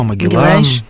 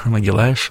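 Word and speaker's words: Magalhães: Вашку де Гама, Магеллан, Тор, да, Магеллаш, вот вы правильно говорите по-португальски.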